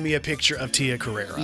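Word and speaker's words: Carrere: Tia Carreri, did I say that right? – I think it's Carrera.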